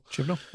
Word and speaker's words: Chibnall: Is that how you say it? Chibnall? – Chibnall, yep.